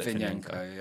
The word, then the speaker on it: Litvinenko: And you're playing Litvinenko. I can't say his name. – Litvinenko, yeah.